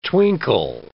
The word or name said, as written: twinkle